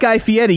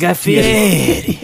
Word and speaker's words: Guy Fieri: So you're not Guy Fieri. You're no one could be. – I like how you said his name properly, too. – Well, I learned that from the McElroys. – Guy Fieri.